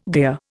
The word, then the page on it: gear: Indian